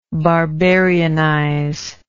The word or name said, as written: barbarianize